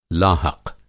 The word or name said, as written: लाहक़